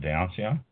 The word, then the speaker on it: Dionysian: That's how you pronounce it, Dionysian. As in the Greek Dionysian Cellars 2010 Seder. – Oh, what? – I don't think that's, that's just their cute thing they're doing here. It's a California red wine, that is what it is. Dionysian, that's how it's pronounced it in the back. They give me pronunciation, Dionysian. I did it right.